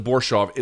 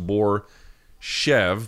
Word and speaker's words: Borshov: They pronounced it Borshov. It looks like it's pronounced Borshev, but I guess it's just Borshov.